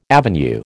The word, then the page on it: avenue: American English